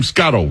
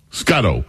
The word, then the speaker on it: Scotto: How do you pronounce her last name? Scotto, right? – Scotto.